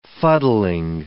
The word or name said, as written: fuddling